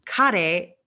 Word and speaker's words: CARE: Care is how Spanish speakers would pronounce a word that is spelled C A R E.